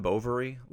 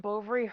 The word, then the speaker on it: Bovary: but Madame Bovary, like just the title is so off-putting to a teenage boy. Like it rhymes with ovaries, it's Madame, right.